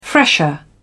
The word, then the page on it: fresher: British English